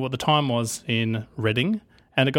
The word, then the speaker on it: Reading: And I don't know if he was trolling us or not, but he wrote the word reading in here about 200 times.